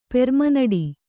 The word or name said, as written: ಪೆರ್ಮನಡಿ